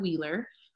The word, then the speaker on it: Wheeler: So Teresa, you probably have it written, but it's LETESHA and Wheeler, just like 18 Wheeler.